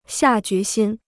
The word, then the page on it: 下决心: xià jué xīn